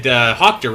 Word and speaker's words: Hochter: And he said, Hochter rhymes with doctor, and I literally almost just said Daniel Doctor.